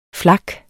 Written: flak

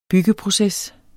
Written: byggeproces

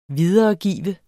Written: videregive

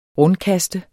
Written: rundkaste

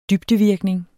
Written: dybdevirkning

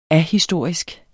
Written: ahistorisk

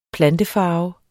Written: plantefarve

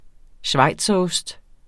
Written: schweizerost

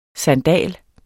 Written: SANDALENS

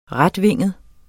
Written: retvinget

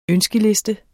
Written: ønskeliste

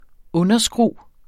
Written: underskru

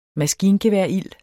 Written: maskingeværild

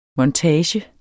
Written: MONTAGER

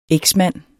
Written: EKSMANDEN

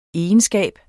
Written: egenskab

